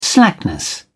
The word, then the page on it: slackness: British English